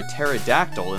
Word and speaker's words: pterodactyl: Why can't you hear a pterodactyl in the bathroom? Because the P is silent.